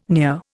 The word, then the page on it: near: Indian